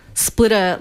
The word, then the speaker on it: Splitr: Splitter is spelt, this is so hard to say, S-P-L-I-T-R. So there's no E there, there's no double T. It's Splitter, like, let's face it, like Grindr.